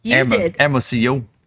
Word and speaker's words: Hermosillo: So, yeah he's saying that we figured out how to pronounce Hermosillo. Hermosillo.